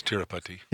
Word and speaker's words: Tirupati: And I guess the difference between American culture and Indian culture is exemplified by the intense energy at the temple at Tirupati. Is that how you pronounce it? – Yes, Tirupati.